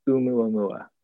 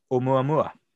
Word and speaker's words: Oumuamua: Oumuamua, you were correct. – Oumuamua.